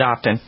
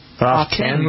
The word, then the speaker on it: often: Often. Where did often come from? – I don't know. Put a T in there. – Often. But the correct pronunciation is often. – Often. – Often. A-W-F-E-N.